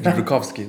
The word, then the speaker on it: Jumrukovski: Did I say it correctly? – Almost. – Oh, Jumbrukovsky. – Jumbrukovsky. There you go.